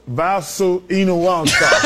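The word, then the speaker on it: Valančiūnas: This is the proper way to say Jonas Valanciunas. – Jonas Valanciunas.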